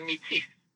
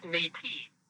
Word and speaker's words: Métis: And those who today can claim this mixed heritage and do so with political clout are the Métis, mispronounced Métis by English speakers.